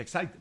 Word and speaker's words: Exide: Dentro se escribe Exide, se pronuncia Excite.